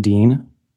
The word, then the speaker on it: Dean: Benjamin Dean, B-E-N-J-A-M-I-N-D-E-A-N.